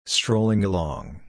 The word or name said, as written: strolling along